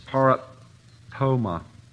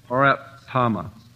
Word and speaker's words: paraptoma: The next one in this section is paraptoma. Proptama. I hope that's right. Wayne was telling me how to pronounce these things. It's P-A-R-A-P-T-O-M-A. But it's got all these Greek pronunciations. Proptama.